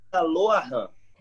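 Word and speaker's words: Rah Ahan: Foi no Ha'aran, correto? – Se pronuncia Lo'aran.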